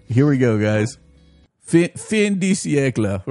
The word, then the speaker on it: fin de siècle: As early as 2000, academics Stephen Sutcliffe and Marion Bowman were commenting on how, this is where the quote begins, in our Fin de Siècle Emporium.